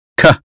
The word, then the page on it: cat: American English